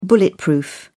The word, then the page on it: bulletproof: British English